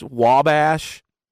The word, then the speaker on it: Wabash: and I see we're crossing Wabash River on the map. – Whoa. – Yeah, which is actually pronounced Wabash, but you know, yeah.